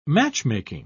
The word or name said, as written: matchmaking